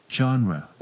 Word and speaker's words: genre: Genre. – What is that? It's called genre. Repeat after me. Genre. Listen to the audio one more time. – Genre. Genre. – Your turn. Genre. Genre. นักเรียนปลายทางออกเสียงตามด้วยนะครับคำนี้เน้นที่พยางค์แรกนะครับเป็นเสียงเจอนะครับเจอ genre don't pronounce it genre no no